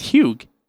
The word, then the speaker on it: Hugh: It's Hugh. It's Hugh. H U G H. Yes, the G is silent.